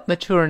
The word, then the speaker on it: mature: 那么一种发音呢，这个字母 t 翻成 t，叫 mature，mature 有美国人这么读的啊。还有一种呢，更多的美国人呢把这个 t 翻成 ch ch，这个声音读成 mature，mature。但后面都是卷舌，这个没错。那我们分别读一下，两种发音都是正确的美式发音。大声来呗，mature，mature，哎，mature，mature 都是正确的这个发音。啊，什么叫 mature 呢？